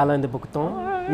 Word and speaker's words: Alain de Botton: And the guy who I usually go to, my go-to guy for such topics is Alain de Botton, who's like, I said that right, didn't I? – Alain de Botton.